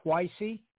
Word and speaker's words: Twicy: And yeah, that song you just heard was from Twicey called Gimme Love. Definitely check out his music on all media platforms. He's a Ghanaian pop singer. And, you know, you spell it T-W-I-C-Y, Twicey, definitely check him out.